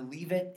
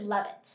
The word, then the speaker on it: Leavitt: The last event I want to talk to you about is the Leavitt AMP Concert Series. Did I pronounce that correctly? – No, it's actually Leavitt.